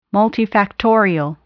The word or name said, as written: multifactorial